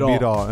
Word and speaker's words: Biro: in realtà Biro, scusa, non okay, Biro. – Perché l'accento è chiuso.